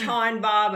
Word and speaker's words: Taughinbaugh: It's Taughinbaugh, but you're not the only one.